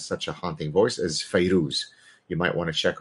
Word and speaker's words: Fairuz: Her name is pronounced in different ways, but probably the most common way, one is Feyrouz or Fayrouz, Fairuz.